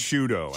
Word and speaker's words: Sciutto: It's Sciutto. Sciutto. I think it's pronounced Sciutto.